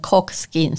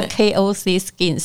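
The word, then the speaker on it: KOC Skin: 嗯真的，我都把它念成 Cock Skin，所以还好我有在问你一下，原来叫 K O C Skin 是一个新创字，这到底是第几年啊？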